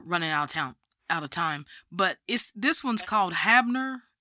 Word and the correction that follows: Hibner: Wait a minute, am I saying this right? Let me look in your book. – Hibner. – Yeah, Hibner. Hibner. Haunting on Hibner.